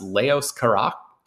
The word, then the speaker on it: Leos Carax: And it is the new movie by I think it's, it's pronounced Leos Carax,